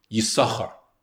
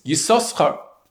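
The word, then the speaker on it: Yissachar: According to Ben Asher, it is to be pronounced just with the one sin, Yisachar. Whereas according to Ben Naphtali, it's pronounced with two sins, Yisoschar.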